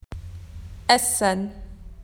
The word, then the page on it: food: Australian English